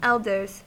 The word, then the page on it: elders: American English